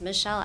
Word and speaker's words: Michelle: It's Michelle Allison Herman. That's M-I-C-H-E-L-L-E. Middle name is A-L-Y-S-O-N. Last name Herman, H-E-R-R-M-A-N.